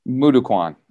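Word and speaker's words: Moo Duk Kwan: So how different is the, I might not pronounce it properly, mukwondo, you said, or? – Mudokwan.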